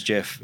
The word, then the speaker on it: Geoff: Because my name's Geoff.